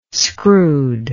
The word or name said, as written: screwed